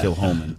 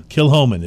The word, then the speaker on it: Kilchoman: I am Kilchoman. – Okay, apparently the C just disappears right out of that. Yeah. Kilhoman. – Kilhoman is how you pronounce it.